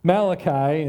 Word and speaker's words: Malachi: My mom used to say it was the only Italian prophet, Malachi. If that helps you, I don't know. Anyway, Malachi is how you pronounce it.